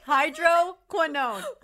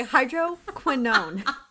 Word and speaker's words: hydroquinone: Say hydroquinone. Oh my God. I can't even. – Hydroquinone. Hydroquinone. Yes. – You really want to get the qui in there.